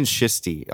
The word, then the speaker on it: schist: Look, just type in schisty on Google. – Well, I made up the word schisty. But schist. S-C-H-I-S-T. Schist, right? Is that how we pronounce it? – Yeah.